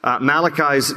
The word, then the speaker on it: Malachi: Malachi's name is pronounced in Hebrew, Malachi.